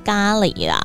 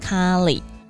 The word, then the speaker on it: 咖喱: 虽然说大家还是习惯性口语上会念咖喱啦，但是正确的发音就是咖喱。 OK，再次跟大家更正这个发音吼。